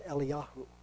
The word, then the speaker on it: Eliyahu: Now, in Hebrew, Elijah is pronounced Eliyahu. And that's the correct way in Hebrew to pronounce it. Now, the reason I say that is because later on we're going to be talking about Elijah.